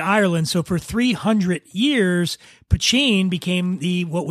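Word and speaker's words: poitín: And when I say Poutine, it's spelled P-O-I-T-I-N, Poutine.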